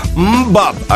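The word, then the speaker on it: MMMBop: Es un título onomatopéyico y eso quiere decir que te voy a pronunciar un sonido gutural, es que esto se llama Mbop, así como suena, son los hermanos Hanson y triunfaban precisamente con esta canción en la segunda mitad de los 90.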